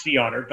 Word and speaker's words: CEOTR: It's called Sea Otter, which is actually spelled CEOTR. – Okay. – I forget what the acronym stands for. Mm-hmm. It's pronounced Sea Otter.